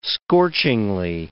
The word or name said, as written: scorchingly